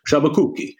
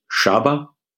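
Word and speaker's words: Sciabacucchi: Chabakuki is at the top of the list, and I explain to lawyers, you just take the name, you break it into two, all right? Chabakuki.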